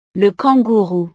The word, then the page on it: le kangourou: frances